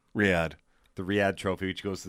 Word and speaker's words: Riyadh: Riyadh. I said Raida.